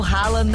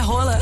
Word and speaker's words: Haaland: Se eu contar pra vocês que a pronúncia do Haaland não é Haaland. – É Hala. – Não, é Rola.